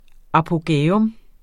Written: apogæum

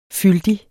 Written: FYLDIGERE

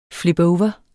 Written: flipover

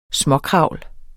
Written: småkravl

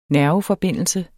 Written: nerveforbindelse

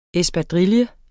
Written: ESPADRILLES

